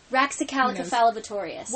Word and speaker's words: Raxacoricofallapatorius: Raxacoricofallapatorius.